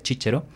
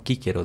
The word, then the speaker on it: Cicero: Y también en la misma época encuentras autores excelentes como Cicerón, con la pronunciación latina que utilizan, la pronunciación eclesiástica se diría Cicero, y los alemanes pronuncian de otra manera, ¿no? Quíquero dicen, pero se refieren al mismo Cicerón.